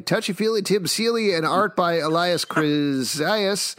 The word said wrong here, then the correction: Kyriazis: Uh, Kyriazis, sorry, I actually know him, so I should probably pronounce his name correctly.